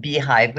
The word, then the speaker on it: Bhyve: So why don't you tell us a little bit, about your company called Beehive, which is spelled, just for fun, Bhyve.